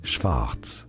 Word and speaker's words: Schwarz: For reference, in German, black is said as Schwarz.